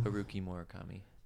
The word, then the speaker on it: Haruki Murakami: Can you say his name one more time? – Haruki Murakami. – And that's how you pronounce it, 100%? Yeah.